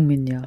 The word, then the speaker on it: меня: Меня, where the м is pronounced soft now because it is followed by е, by the letter е.